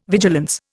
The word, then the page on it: vigilance: Indian